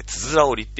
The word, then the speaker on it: 九十九折り: あの、つづら折り、ね、九十九折りって書いて、つづら折りって読みますけど、